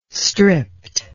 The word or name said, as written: stripped